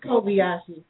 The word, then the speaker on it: Kobayashi: and I'm going to say his name wrong. Don't it's Kobayashi.